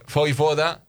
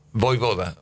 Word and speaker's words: Vojvoda: Vojvoda. – Vojvoda. – Vojvoda. – Ah, Vojvoda. – Que no. – Sí. – Sí, porque la J se pronuncia ahí.